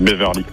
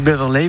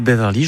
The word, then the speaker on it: Beverley: C'est Patrick Beverley. – Oui, vous le prononcez mieux que moi. Moi j'ai dit Beverley, vous dites Beverley, je pense que c'est vous qui avez raison.